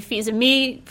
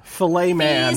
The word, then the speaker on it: Fils-Aimé: I also got to speak with Reggie Fils-Aimé from Nintendo.